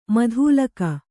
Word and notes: ಮೂದಲಿಕೆ: mūdalike

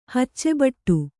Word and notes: ಹುಚ್ಚುಬಿಡು: huccu biḍu

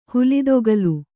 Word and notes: ಹುಲಿದೊಗಲು: Kannada

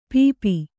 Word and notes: ಪೀಪ: pīpa

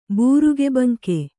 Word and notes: ಬೂರುಗಬಂಕ: būruga banka

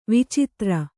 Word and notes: ವೈಚಿತ್ರ: vaicitra

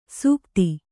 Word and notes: ಸುಗತಿ: sugati

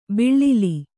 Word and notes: ಬಿಳ್ಳಿಲಿ: biḷḷili

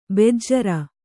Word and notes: ಬೆಜ್ಜರ: bejjara

